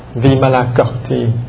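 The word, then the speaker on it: Vimalakirti: Vimala Kirti thì tàu, phát âm là Vimala Kirti thì tàu dịch là Duy Ma Cập.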